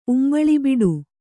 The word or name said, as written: ಉಂಬಳಿಬಿಡು